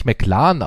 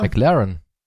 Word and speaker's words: McLaren: Aber das spricht man doch nicht McLaren aus. – Wie heißt das Auto? – McLaren.